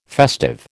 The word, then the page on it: festive: American English